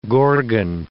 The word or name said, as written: gorgon